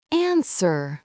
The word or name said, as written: answer